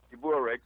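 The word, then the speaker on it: Lstiburek: I call him Stiburek. That's the right way to pronounce it.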